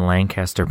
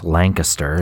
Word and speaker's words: Lancaster: So on a cold December morning in 1992 in Lancaster, Pennsylvania. I said that wrong. It's Lancaster, as they say in Pennsylvania.